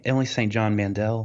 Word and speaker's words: Emily St. John Mandel: Emily, is it Emily St. John Mandel? – That's it.